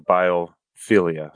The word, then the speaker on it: biophilia: You talked about the concept of biophilia. Is that how you say it? – Yes.